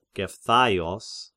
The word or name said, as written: Gepthaios